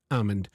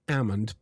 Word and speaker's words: almond: For example, the word almond, it's A-L-M-O-N-D, almond. I would say almond, but a lot of people that I have spoken about this with, they usually tell me, no, I pronounce the L. I say the L. I say almond.